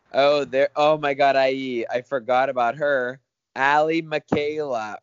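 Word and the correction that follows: Michalka: or Allie, how do you say her Majaka.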